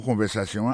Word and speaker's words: conversation: Mot conversation, accent tombé sur avant-dernier syllabe là, you know.